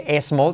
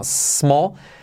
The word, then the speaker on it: small: Semiclave S no se dice e small, sino small letra clave L al final, small, small town.